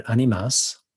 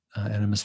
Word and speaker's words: Animas: And animus, that's a mispronunciation of the Spanish word animas, which means souls. And we call it Animus Valley Institute because that's where we are in Southwest Colorado.